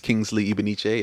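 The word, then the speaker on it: Kingsley Ibeneche: So my name is Kingsley Ibeniche, and I'll spell that for you all. – Thank you. – K-I-N-G-S-L-E-Y, last name I-B-E-N-E-C-H-E.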